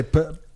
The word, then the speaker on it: pernil: So how do you say pernil? – Pernil.